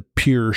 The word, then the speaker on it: Pierre: And that's Pierre, but it's spelled like Pierre. I always think of it P-I-E-R-R-E, Shale. So you might see it, you know, spelled and think, oh, that's not what we're saying.